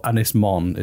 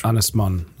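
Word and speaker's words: Ynys Môn: Anglesey or Ynysmon is the Welsh. – Ynysmon.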